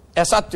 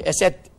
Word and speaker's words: Esed: Bu Hafız Esed'i, Esat diyorlar da Esat değil, Esed diyorum ya. Esed aslan demek yani. Esat, Said adam demek. Onun için bütün telaffuzlarda Esat diyorlar da Esed.